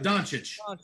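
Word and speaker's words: Dončić: Yep, Doncic.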